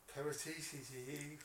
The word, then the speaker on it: Paratici: Patrici? – Yeah. – And how'd you pronounce that? You know how to pronounce it. Paratici. – It isn't. It's Paratici, isn't it? – Paratici.